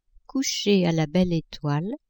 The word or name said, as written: coucher a la belle etoile